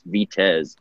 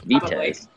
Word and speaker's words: Vitez: And he's the horse that he's on this race, Vitez, yes. which I found out is a Polish pronunciation. I don't know if you can speak Polish, Louis, but it's don't. – Vitez.